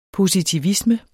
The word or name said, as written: positivisme